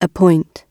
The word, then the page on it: appoint: British English